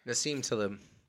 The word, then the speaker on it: Nassim Taleb: Nassim Taleb. – Yeah. Spell it. No, just kidding. – N-A-S-E-E-M-T-A-L-I-B, I think.